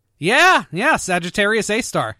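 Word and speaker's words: Sagittarius A*: Well, star number three, sad to say, is Sagittarius A-star, which it's pronounced A-star, but it actually refers to the supermassive black hole at the center of the Milky Way galaxy.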